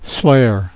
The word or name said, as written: slayer